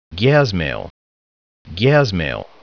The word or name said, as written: GyazMail